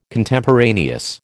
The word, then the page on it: Contemporaneous: American English